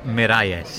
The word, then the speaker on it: Miralles: At least Matt knows how to say his name. I butchered it yesterday. What's his name again? – Bernabe Zapata Miralles.